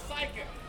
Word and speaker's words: psychic: I think you mean a psychic. The P is silent.